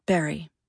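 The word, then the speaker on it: Barre: Most locals pronounce it Barry, though there's a small faction of lifers who insist it rhymes with fury.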